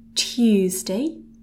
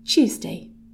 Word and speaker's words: Tuesday: And in between the letter T and the letter U, we've got a bit of a Y sound going on. So we can pronounce it as Tuesday. Or as people speak more quickly, it kind of turns into something a little bit more like a Ch sound.